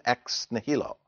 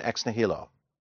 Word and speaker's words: ex nihilo: How do you pronounce it, Brian? With the H or without? Okay. Yeah, I've found both. So for some reason, I like pronouncing the H. So ex nihilo.